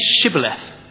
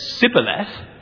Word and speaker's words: Shibboleth: If he replied, "No," they said, "All right, say Shibboleth." And if he said Sibboleth, because he couldn't pronounce the word correctly, they seized him and killed him on the fords of the Jordan.